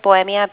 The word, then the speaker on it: Bohemia: My first name is Bohemia, B-O-H-E-M-I-A.